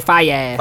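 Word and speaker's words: Faiyaz: I say Fias.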